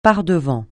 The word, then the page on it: par-devant: francais